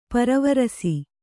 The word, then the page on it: ಪರವರ್ಷ: paravarṣa